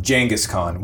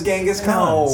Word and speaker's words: Genghis Khan: But I mean, there comes a point at which, you insisting on GIF is basically the equivalent of people insisting that you pronounce Genghis Khan, Genghis Khan, which is actually the correct pronunciation, but...